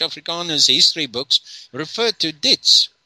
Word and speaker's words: Diets: developed out of this ditch. I mean, this dish. Dietz. – How do you spell it? – D-I-E-T-Z? – D-I-E-T-S.